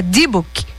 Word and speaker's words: Dibuk: Sim, é o espetáculo, eu acho que eu errei, eu acho não, tenho certeza que eu errei na pronúncia, é Dibuk.